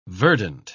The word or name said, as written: verdant